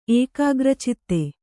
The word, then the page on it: ಏಕಾಗ್ರಚಿತ್ತೆ: ēkāgracitte